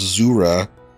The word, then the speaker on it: Zura: Well, first of all, in Pathfinder at least, pronounced Zura like a zoo, but she is a demon lord, also known as the Vampire Queen.